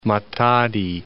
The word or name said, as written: Matadi